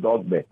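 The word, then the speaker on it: Dogboe: But I don't discard Dogbo. Dogbe. Dogbe will be Dogbe. Pronounced Dogbe.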